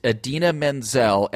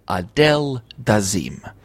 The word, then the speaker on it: Idina Menzel: mispronounced Idina Menzel and said the wickedly talented Adele Dazeem.